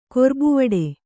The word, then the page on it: ಕೊರ್ಬುವಡೆ: kannada